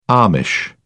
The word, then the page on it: Amish: British English